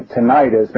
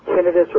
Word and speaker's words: tinnitus: Yeah, tinnitus or tinnitus, and incidentally, both are accepted pronunciations depending on what part of the country you're from.